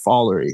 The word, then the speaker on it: Follery: and it's actually pronounced follery.